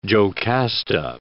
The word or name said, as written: Jocasta